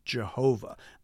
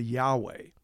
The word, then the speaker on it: Yahweh: And then in the 6th century AD, it became common to put the vowel markings for Adonai around the consonants for Yahweh to help remind people how they were supposed to pronounce it. And that actually produced the Latinized pronunciation of Jehovah, the vowels from one word and the consonants from another. But the original pronunciation was actually closer to Yahweh.